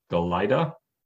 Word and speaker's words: Galajda: So our third player, third goalie, is the Big Ten's Notre Dame, and their goalie is, I, I believe it's pronounced Matthew Galajda, G A L A J D A.